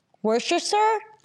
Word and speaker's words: Worcestershire: And is how do you say it? Worcestershire? I can't say that word. You say it, Brad. – No, you said it right.